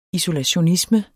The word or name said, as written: isolationisme